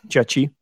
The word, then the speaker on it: Jiaqi: like Jiaqi, excuse me if I'm not mispronouncing your name, by the way, Jiaqi, Jiaqi.